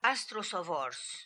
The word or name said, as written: Astrosovors